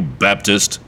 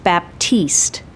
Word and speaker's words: Baptiste: Are you Baptist? – Are you asking my name or my religion? – What? – All right, I presume you're asking my name. It's pronounced Baptiste. Bridget Baptiste.